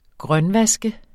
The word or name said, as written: grønvask